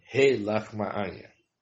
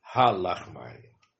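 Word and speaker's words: He Lachma'anha (הא לחמא עניא): A primeira palavra da Agadá pronuncia-se He Lachma'anha, e não Ha Lachma'anha.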